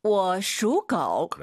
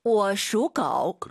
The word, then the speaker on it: 我属狗: 我属狗. – 그렇죠. 워 올라간 순간 틀린 발음이에요. 워, 슈, 고. 삼성이 마지막 글자만 올리지 마시고요.